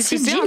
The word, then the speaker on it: sigils: C'est sigils. un sigil Non, qu'est-ce que c'est Ça s'écrit S-I-G-I-L.